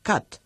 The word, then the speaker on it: cut: Por ejemplo, cut, el verbo cortar, que se escribe C-U-T y, sin embargo, se pronuncia con A.